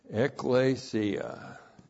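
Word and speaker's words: ekklesia: Ecclesia, E-K-K-L, long E-S-I-A.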